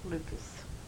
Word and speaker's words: Lucas: Mary Catherine Lucas, L-U-C-A-S. – How do you spell your Catherine? – With a C, C-A-T-H-E-R-I-N-E.